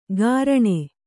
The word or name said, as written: ಗಾರಣೆ